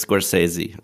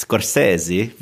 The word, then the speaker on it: Scorsese: hat es auch vor nicht allzu langer Zeit nochmal in einem Interview gesagt, wie man es ausspricht. Also es ist Scorsese.